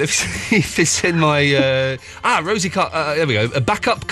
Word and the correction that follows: Bacup: coconutters. – Yeah, bake-up. – Bake-up, is that how you pronounce it? – Yeah. – It's, okay, listen, everyone, if you're at home, go and type this into Google. Bake-up, B-A-C-U-P, coconutters.